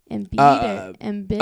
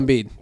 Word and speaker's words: Embiid: Embiid. – Oh, Joel Embiid.